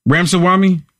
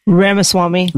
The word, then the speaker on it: Ramaswamy: Listen, listen to, I know I butcher his name every time. Ramsawam, Ram, how you say it? Ramsway, Ramsawami, Ramsaw, how do you say it? I butchered every time. – Ramaswamy.